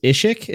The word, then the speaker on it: Ishikk: Yeah, interlude one is Ishikk.